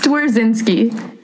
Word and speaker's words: Dzwierzynski: It's Dzwierzynski.